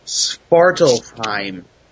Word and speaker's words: Svartalfheim: as in the ruling Dark Elf of Svartalfheim.